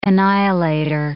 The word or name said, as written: annihilator